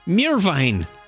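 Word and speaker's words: Meerwein: And born 1879, German chemist Hans Mirwin. – Mirwein. – Oh, excuse me.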